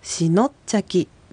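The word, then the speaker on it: シノッチャキ: しのっちゃき、第一音節はし、 SI ですので I が母音なので次の音節のの部分にアクセントが来てしのっちゃきとなります。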